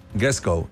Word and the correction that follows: dress code: Es con Gresco. – Ah, código de... – No, con dress code.